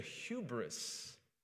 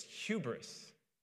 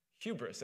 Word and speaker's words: hubris: Finally, after years of him coming to me and saying, "Fix," I think I've mentioned it 1 or 10 times, but it's not hubris, it's hubris. Hubris.